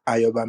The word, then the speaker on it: Ayo: My name is Ayoba Migbe. But for my friends across the South African region and the Kenyan region, and even some Nigerians, I say Ayo is okay.